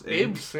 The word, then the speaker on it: Abes: Abes or Apes? – Abes, yeah. Yeah.